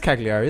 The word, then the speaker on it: Cagliari: It is Cagliari, isn't it? – Yes, it is.